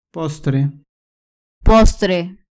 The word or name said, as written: postre